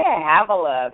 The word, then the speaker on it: Havilah: Yeah, Havilah, that's it.